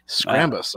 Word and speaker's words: Scrambus: Like, how do you pronounce this? – Scrambus, uh, like scrambled. Like, okay.